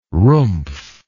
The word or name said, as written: rumpf